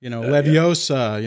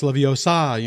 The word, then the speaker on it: Leviosa: And if you don't say it the correct way, I mean, I'm thinking like Harry Potter, you know, Leviosa, you know, no, it's Leviosa, you know, I mean, it's like, if you don't say it right, it doesn't work.